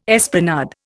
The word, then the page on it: esplanade: Indian